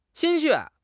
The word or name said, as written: 心血